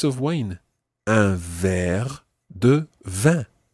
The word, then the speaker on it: vin: Le vin is wine. Le vin. – Le vin. – It sounds exactly the same as the word for 20. Vin. – Vin. – But it's spelt differently. It's V-I-N.